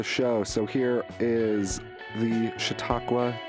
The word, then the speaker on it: Chautauqua: And you guys are coming through Fort Wayne as part of the Chautauqua Tour.